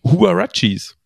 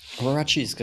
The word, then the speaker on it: Huaraches: Und dann, und dann zufällig in einem Artikel gelesen, wie die denn tatsächlich ausgesprochen werden. Das sind Huarachis. – Huaraches, genau.